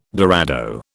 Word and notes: dorado: British English

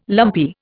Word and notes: lumpy: Indian

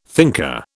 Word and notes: thinker: British English

